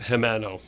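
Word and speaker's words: Jimeno: That's how you pronounce it. It looks like it's, if you look it up, it's J-I-M-E-N-O. – Right. Will Gimeno. – But it's pronounced Gimeno.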